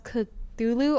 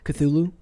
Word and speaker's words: Cthulhu: So, like, if you know, is it pronounced Cthulhu or... – Cthulhu? – Yeah, some people say you're supposed to actually pronounce the C, and I'm like, I can't do it.